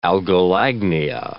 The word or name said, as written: algolagnia